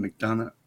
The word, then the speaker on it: McDonough: McDonough.